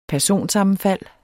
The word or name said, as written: personsammenfald